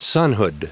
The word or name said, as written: sonhood